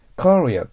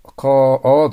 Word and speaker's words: courier: その次、 courier と発音します。 courier です。ベロを下に落として喉の奥を医者に見せてください。 courier です。